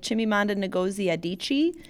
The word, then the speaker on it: Chimamanda Ngozi Adichie: Okay, so then my favorite book of 2017 was Americana by Chimamanda Ngozi Adichie. – Great. – Did you read it? – No, I was just saying your pronunciation. – Thank you. – Spot on.